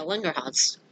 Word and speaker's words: Langerhans: Yeah, Langerhans. – Yep. – Okay, see? You were adding a syllable, but that's okay. – Anyway. – Oh, you never mind, you were just combining the two words.